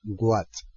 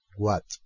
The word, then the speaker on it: watch: os diga what. ¿eh? Esto es porque el voice software está en castellano y pronuncia tal y como se escribe. Y la palabra se escribe W-A-T-C-H. What. Si vosotros queréis que os lo pronuncie correctamente, que la pronunciación es what,